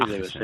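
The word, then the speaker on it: Aagesen: Que se llama Sara Agesen, que por visto se pronuncia así, Agesen, que es un apellido de origen danés. – Pues sí, Agesen, debe ser, sí.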